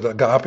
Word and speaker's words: agape: Some pronounce it agape, some pronounce it agape. It doesn't matter.